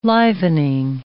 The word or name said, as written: livening